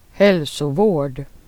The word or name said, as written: vård